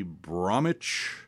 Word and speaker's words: Bromwich: Born in, okay, my wife might correct me being from England, but I believe it would be Bromwich, England, because they don't pronounce the W's in so many words, like Norwich, or, oh, like actually in Manhattan, Greenwich, named by some Englishman, I'm sure.